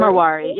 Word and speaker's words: Marwari: Um, and one other um, one other chapter was about the Mar Marwar, and I'm not sure if I'm pronouncing this Marwari. – Marwari, yeah. Um, mm-hmm.